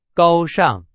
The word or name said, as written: 高尚